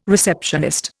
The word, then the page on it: receptionist: Indian